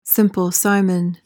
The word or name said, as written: Simple Simon